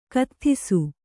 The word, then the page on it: ಕತ್ಥಿಸು: katthisu